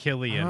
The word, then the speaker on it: Cillian: Yeah, well, it's Killian.